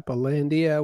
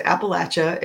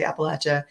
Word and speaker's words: Appalachia: The correct way to pronounce it is Appalachia if you're West Virginia or south of that. I don't know what those Yankees say, but down here we say Appalachia. And the way you remember it is if you don't say it right, I'm going to throw an Appalachian.